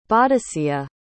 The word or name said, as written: bodicea